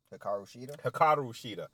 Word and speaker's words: Hikaru Shida: And, but like, Hikaru Shida, is that how you say her name? – Hikaru Shida.